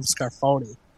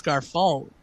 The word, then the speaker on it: Scarfone: They just kept calling him Scarfoni. And I said, no, it's Scarfone. Like, you're saying it wrong.